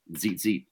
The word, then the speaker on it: tzitzit: So T-Z-I-T-Z-I-T, tzitzit, or sometimes it's pronounced tzitzit.